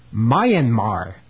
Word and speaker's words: Myanmar: Myanmar.